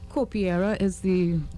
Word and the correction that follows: Capoeira: Capoeira mm-hmm.